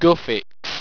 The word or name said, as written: gufix